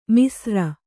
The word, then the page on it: ಮಿಸ್ರ: misra